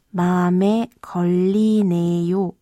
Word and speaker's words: 맘에 걸리네: Wenn Sie den Ausdruck gegenüber jemandem äußern sollten, den Sie siezen, sollten Sie noch am Ende das Höflichkeitssuffix yo anhängen, also Bame kollineyo. Lassen Sie uns aber heute noch einmal die Aussprache der nicht höflichen Form aus der Szene zusammen üben. Sprechen Sie bitte nach Bame kolline. Ich w i 마음에 걸리네.